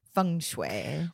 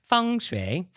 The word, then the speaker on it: feng shui: Yeah, and if you want to pronounce it correctly. Feng shui. – Wind and water. Feng shui.